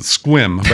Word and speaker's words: Sequim: You know, the natives pronounce it Squim, but everyone else pronounces it Sequim.